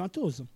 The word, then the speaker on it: Atoz: Alors revenons un petit peu sur, déjà on va commencer par Atos, les éditions Atos. Qu'est-ce donc ce mot un petit peu bizarre, Atos, A-T-O-Z ? – À la base c'était A2Z et puis évidemment la prononciation anglophone, ben, c'est devenu Atos.